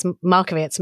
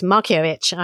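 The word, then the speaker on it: Markievicz: Well, look, I mean, first of all, I've been pronouncing her name because I read the poetry as Markovits, Markiewicz.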